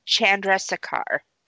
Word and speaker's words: Chandrasekhar: Uh, his name is Jay Chandrasekhar.